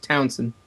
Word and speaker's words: Townshend: It's spelt Townshend, but I asked him, and it's just Townshend.